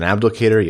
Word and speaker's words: Abdelkader: Some mild pieces of news, some smaller pieces of news regarding, you know, Jamel Smith and Justin Abdelkader.